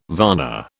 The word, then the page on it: varna: British English